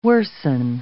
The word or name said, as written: worsen